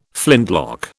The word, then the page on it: flintlock: British English